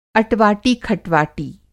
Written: अटवाटी-खटवाटी

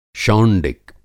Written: शौंडिक

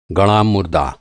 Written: गड़ा

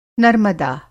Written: नर्मदा

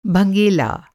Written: भँगेला

